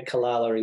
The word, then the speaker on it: Calala: Is it Calala? I don't know how to say it exactly. Is it? – Yeah, Calala in Tamworth. – Calala in Tamworth.